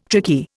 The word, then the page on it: tricky: Indian